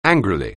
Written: angrily

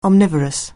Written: omnivorous

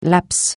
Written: laps

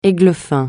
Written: églefin

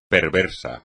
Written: perverso

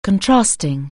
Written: contrasting

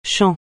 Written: chant

